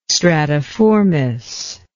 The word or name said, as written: stratiformis